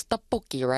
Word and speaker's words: 떡볶이: 텍바키가 뭔가요? 아무리 급하게, 아무리 배고파도 그렇지, 텍바키 말고 떡볶이라고 해야 되는데. But I do understand. It's really hard to pronounce 떡볶이, right?